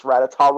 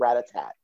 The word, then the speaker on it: Rattata: I was like 6 though, and I couldn't pronounce Rattata right, so I called it Rattatat.